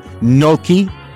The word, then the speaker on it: gnocchi: You can choose from over 100 delicious recipes every week, from Cajun spiced chicken to poached salmon to butternut squash, gnocchi.